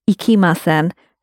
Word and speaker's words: ikimasen: And in the foundation course, we were quite strict on how you pronounce the word ikimasen, to not go. And the reason why we were particular about your pronunciation, because sometimes ikimasen can sound quite like ikemasen, and they mean completely different things.